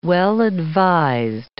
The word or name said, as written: well-advised